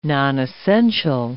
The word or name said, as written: nonessential